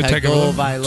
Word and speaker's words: Tagovailoa: Did I say his name right? I think that's right? – Tua Tagovailoa. – Tua Tagovailoa. Yeah.